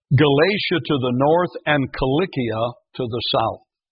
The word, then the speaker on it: Cilicia: It's Cilicia with a hard K, a sound. The C is hard, and if you look it up in the Greek, it is spelled with a kappa, with a K.